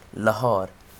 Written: Lahore

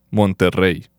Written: Monterrey

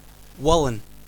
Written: Wallan

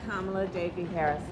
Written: Kamala Devi Harris

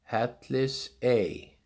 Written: Hellisey

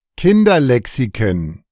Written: Kinderlexiken

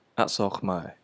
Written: Khmer-Schrift

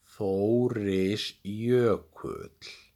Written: Þórisjökull

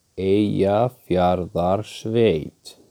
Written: Eyjafjarðarsveit

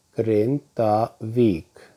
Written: Grindavík

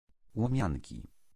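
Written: Łomianki

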